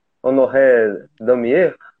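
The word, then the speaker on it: Honoré Daumier: Honoré Damier. Eu não sei se fala assim a pronúncia. – É isso mesmo.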